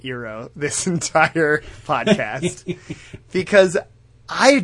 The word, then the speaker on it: Iroh: But, like, that is how it would be pronounced in Japanese. And, like, in my brain, this is, like, an Asian culture. And so I, like, default to the Japanese pronunciation, yeah, which is the one that I have a passing familiarity with. And so it would be Iroh.